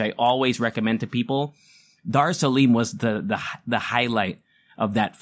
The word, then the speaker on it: Dar Salim: His name is Dar Salim. I've never seen him on film before. Darsalim. I hope I'm saying that right. Let me look it up. Yeah, Dar Salim.